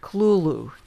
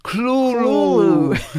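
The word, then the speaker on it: Cthulhu: When I learned how to pronounce the name Thulu, am I doing it right? – Clulu. – Clulu.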